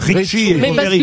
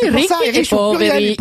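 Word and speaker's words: Ricchi e Poveri: Mais non, mais Ricky et Poveri, c'est riche et pauvre. – Mais oui, bah oui, Ricky et Poveri. Et si, à l'accent tonique, Poveri.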